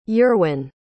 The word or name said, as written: eurwen